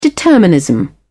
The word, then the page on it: determinism: British English